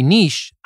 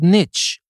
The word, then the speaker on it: niche: And by the way, niche, I know some of you will say that we've heard this word before. It's called niche and not niche. Well, actually, it is pronounced both ways.